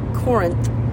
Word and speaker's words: Corinth: We are about 20 miles out of Corinth.